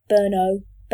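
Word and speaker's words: Burnow: Burno. – Not Burnham. – How you pronounce it? No. N-O-W.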